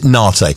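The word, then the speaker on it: Naté: Or we're going to pronounce it Nate. Ultra Nate is the name of the band.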